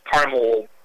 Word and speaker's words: caramel: But if you look, like, on caramel corn or caramel ice cream topping, it's C-A-R-A-M-E-L. – Yes, yeah. – Which we would say was caramel. – It sounds right, yes. – Yeah, but for some reason we've taken the pronunciation of that word and changed it quite a bit. – We have. – Nobody says caramel.